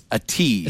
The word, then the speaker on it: Ativ: First of all, we had a hard time knowing how to pronounce it. It's Ativ.